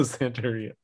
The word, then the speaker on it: Santeria: It's called Santeria with an American pronunciation. So Santeria. And that's basically...